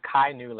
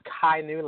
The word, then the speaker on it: Chi Nu: So it's actually pronounced Kai New Legacy. – Kainu. – No problem. We get that a lot. We get a lot of different pronunciations of it. But it's Kainu Legacy.